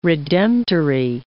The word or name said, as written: redemptory